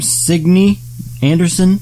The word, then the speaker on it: Signe Anderson: Signe Anderson? – Yes.